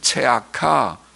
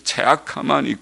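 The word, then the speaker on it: 체아카 (צְעָקָה): 원문에는 공평과 포악, 공평이 미슈파트, 히브리 말은 미슈파트, 포악이라는 말은 미스파크, 발음이 비슷하죠. 미슈파트, 미스파크, 또 의로움이란 말은 체다카, 그리고 부르짖음은 체아카. 하나님께서는 비슷한 발음의 두 단어를 사용하시면서 대조하십니다.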